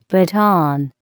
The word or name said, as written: baton